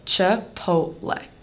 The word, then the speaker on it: Chipotle: The correct way to say Chipotle is Chipotle.